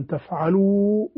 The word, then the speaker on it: تفعلوا: فإن لم تفعلوا ولن تفعلوا، هذه الألف هي موجودة في الرسم لكن لا تنطق، لا اعتداد بها في النطق.